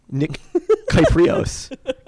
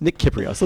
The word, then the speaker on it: Nick Kyprios: No, that R is before the E. Nick Kyprios. Kyprios? Nick Kyprios. Let's go with that.